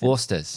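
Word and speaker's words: Foster's: That's probably not how do you say Fosters. – Australia. Yeah, no. Yeah. I wonder how you say it, like, in an Australian accent. – Forsters. – Forsters. Australian for beer.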